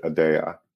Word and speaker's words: Aadya: It's not. It's Aadya. And it's intentional. Aadya. Yep. If you look it up, it is Aadya. That's how it's pronounced in Latin or the Middle East, but we pronounce it Aadya.